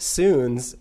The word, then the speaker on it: SUUNS: You guys, the name, it's pronounced SUUNS, but for those of you who don't know, it's spelled S-U-U-N-S, capitals.